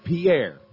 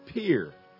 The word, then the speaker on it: Pierre: You look at a map, you might pronounce it Pierre. A lot of folks do. But around here, they pronounce it Pierre, South Dakota.